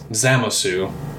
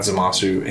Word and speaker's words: Zamasu: So that spawns like an alternate timeline where I've been saying his name wrong, I just realized, but it's like a subtle difference. It's Zamasu, not Zamasu.